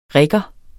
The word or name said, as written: rigger